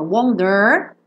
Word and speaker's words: wonder: I wonder. E ele também não canta wonder, que seria bem americano, wonder. It can't stop, I wonder, wonder, wonder. É bem britânica essa pronúncia.